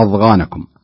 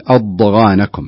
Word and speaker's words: أضغانكم: أيضا في قوله تعالى: أضغانكم، يعني بعض الناس ينطقها أضغانكم بالضاء بعصا، والصحيح أنها ضاد مثل الضالين، مثل كلمة الضالين الضاء، فتقول أضغانكم، تحاول أن تمسك الحرف ولا تخرج اللسان، تمسك الحرف بأقصد لا يكون هناك قلقلة.